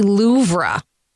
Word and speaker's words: Louvre: I saw a local TV person say the Louvre. – It should be Louvre. But we always say Louvre, don't we? – Yeah.